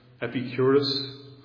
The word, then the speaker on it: Epicurus: Then there's the what's called trilemma of the Greek philosopher, and I'll probably get the name wrong again, Epicurus. Am I right? Epicurus? Yes. And his trilemma is summarized by the 18th century Scotsman David Hume this way.